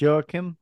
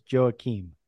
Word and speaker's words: Joachim: Saint Joachim or Joachim? Either one, I believe, is correct.